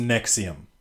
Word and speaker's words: NXIVM: Anyway, this prosecutors accused blah blah blah of running a cult like secret society with his organization called NXIVM, pronounced Nexium, in which women were branded with his initials, put on extremely restrictive diets, and forced to have sex with him.